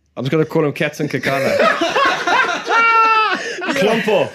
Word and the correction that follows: Klumpy: You say Klumpy.